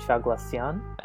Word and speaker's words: Chaglassian: But you pronounced it really differently from the way that I pronounced it, because your emphasis... I think I was putting emphasis on Chaglassian and you said Chaglassian. – But the issue there is that I come from a multicultural background.